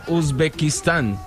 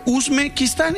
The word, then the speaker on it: Uzbekistán: Que Uzbekistán. – Muy bien, ahí está, breve. Uzbekistán y ya.